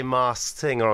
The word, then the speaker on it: Masked Singer: It's because I'll be honest, every single time I say Masked Singer on the show, I just completely forget the K because it's quite difficult to pronounce.